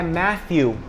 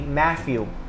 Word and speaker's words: Matthew: Por exemplo, meu nome, em inglês, claro, é Matthew. Mas pessoas colocam como fosse um F, Matthew. Você pode perceber que é bem parecido, mas não é.